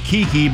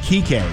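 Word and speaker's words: Kiké: Also, I know his name is pronounced Kiki, but I really wish I still was pronouncing it. It's actually pronounced Kike.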